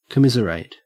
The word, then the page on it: commiserate: Australian English